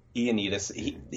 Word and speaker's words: Ioannidis: And in fact, and I'm not going to get how you say his name right, John Ioannidis, I think. – Yeah, I think it's Ioannidis. – Ioannidis,